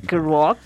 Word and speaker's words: Kerouac: Es Kruak? ¿Kruak? ¿Cómo se pronuncia? – para mí es imposible también. – Eh, Kerouac.